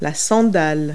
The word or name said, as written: sandal